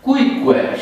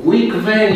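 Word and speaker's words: cuique: Cuicue. Și spui de 10 ori până ți iese. Deci nu cuicve, nici cuiche. Deci n-are nicio legătură cu pronunțiile de tip spaniol sau... Da? Porqué. Aia e spaniolă, nu e în latină.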